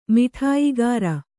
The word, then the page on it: ಮಿಠಾಯಿಗಾರ: Kannada